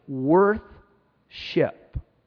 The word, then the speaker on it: worship: Worth-ship is a good way to pronounce it, because that's literally what it is.